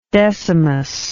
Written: decimus

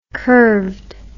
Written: curved

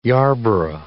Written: Yarborough

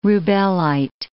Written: rubellite

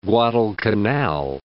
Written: Guadalcanal

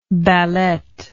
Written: ballett

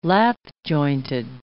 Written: lap-jointed

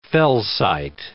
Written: felsite

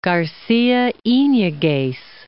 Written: Garcia iniguez

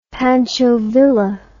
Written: Pancho Villa

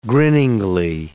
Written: grinningly

